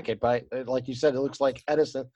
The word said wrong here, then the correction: Essendon: I'm not saying that right. – Essendon.